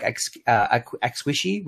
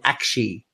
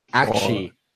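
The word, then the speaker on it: Aqshy: have both told me that the way you pronounce it is not as in a hug, like ex-wishy. We're calling it that. It's called Akshi. – Akshi.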